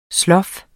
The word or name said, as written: slof